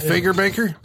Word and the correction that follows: Fagerbakke: It's Fagerbakke, actually. I just learned that the other day. That's how you pronounce it.